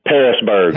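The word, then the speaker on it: Pearisburg: What is the you've told me this before, but I don't want to mispronounce it. What is the name of this nowhere town? Pierisburg? – Parisburg. – Parisburg.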